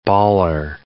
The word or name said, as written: baller